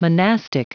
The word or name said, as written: monastic